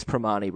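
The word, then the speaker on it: Primanti: I think the T is silent. I think it's Primati Brothers.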